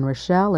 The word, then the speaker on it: Rochelle: My name is Rita, and that's spelled R-I-T-A, and Rochelle is spelled R-O-C-H-E-L-L-E.